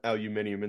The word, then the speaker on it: aluminium: Put uh, aluminium in the chat, which is how you say it if you're British.